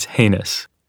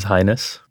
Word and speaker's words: heinous: it's heinous which I mispronounce it as highness.